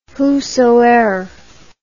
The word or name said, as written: whosoeer